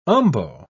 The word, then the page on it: umbo: British English